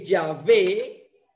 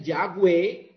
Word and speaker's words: Yahvé: Porque Yahvé, algunos lo pronuncian Yahweh, porque la Bau la leen como W, pero nosotros hicimos el ejercicio, y Dawi no suena bien, suena el David con V. Entonces uno lo leen como Yahvé, otro lo leen como Yahweh.